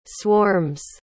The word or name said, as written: swarms